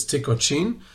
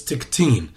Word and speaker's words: Tykoczyn: So, but it's in Polish, it's Tykoczyn. Really, I think the accent is on the second syllable, if I'm not mistaken. And in Yiddish, it's Tiktin.